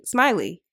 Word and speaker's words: Smiley: Yeah, my last name is actually Smiley.